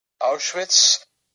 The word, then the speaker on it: Auschwitz: Auschwitz.